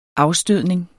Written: afstødning

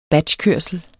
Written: batchkørsel

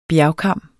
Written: bjergkam

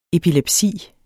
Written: epilepsi

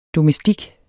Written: DOMESTIKKER

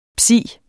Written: psi

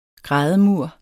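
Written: GRÆDEMUR

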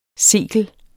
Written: sekel

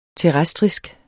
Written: terrestrisk

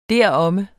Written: deromme

